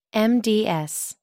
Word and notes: mds: American English

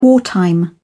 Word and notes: wartime: British English

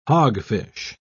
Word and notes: hogfish: British English

